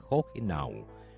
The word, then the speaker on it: Hohenau: Y venimos revisando el origen de la primera colonia de inmigrantes alemanes, en este caso, que es Hohenau o Hohenau, como nos dijeron desde Hamburgo, Alemania, que se pronuncia Hohenau. Pero nosotros, a lo nuestro, Hohenau.